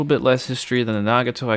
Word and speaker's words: Nagato: Today in Rank Amateur we will be doing the Tech Tree uh, Tier 7 Japanese battleship Nagato, or Nagato, however you want to pronounce it.